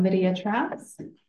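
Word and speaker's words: Lydia Trowse: M-I-C-H-A-L-S-K-I. Thank you. – Hi, I'm Lydia Trouse. L-Y-D-I-A, and Trouse is T-R-O-W-S-E.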